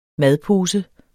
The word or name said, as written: madpose